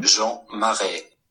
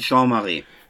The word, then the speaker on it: Jean Marais: Ich habe hier nochmal eine zweite Aussprache zu Herrn Marais. Ne, nicht, dass wir jetzt hier die ganze Zeit den Namen falsch sagen. – Jean Marais. – Scheint aber richtig zu sein.